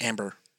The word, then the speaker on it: Ambar: A-N-A-M-B-A-R. Is that how you pronounce that? Ambar? – That's Amber.